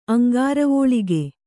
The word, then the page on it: ಅಂಘ್ರಿವಲ್ಲಿಕೆ: aŋghrivallike